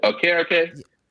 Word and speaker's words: Okereke: who to me are Lawrence Okiki. I know that's not how you say his name. Thibodeau. And those two. – You said, are you talking about Okereke? – Okiki, yeah, him.